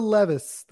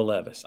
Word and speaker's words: Levis: His name is Will Levis, the quarterback from the University of Kentucky. – Will Levis. I always want to call him Will Levi's. I never say his name right.